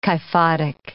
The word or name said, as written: kyphotic